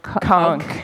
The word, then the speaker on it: Kunk: Kunk. – K-U-N-K.